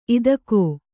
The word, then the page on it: ಇದಕೋ: idakō